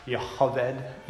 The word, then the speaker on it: Jochebed: My mom was very helpful last Sunday with letting us know that's Jochebed, you know, is how you would pronounce her name.